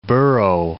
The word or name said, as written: burro